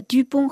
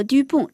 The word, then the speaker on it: Dupont: 看过丁丁历险记的朋友们一定会记得 Dupont 和 Dupont 这两个几乎是连生兄弟的滑稽可笑的侦探的名字。他们两人都姓杜鹏，读音读一样，但这两个姓却不尽相同，区别就在于最后一个字母上。